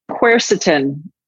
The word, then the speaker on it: quercetin: Can you just briefly, because quercetin, is this, am I yeah.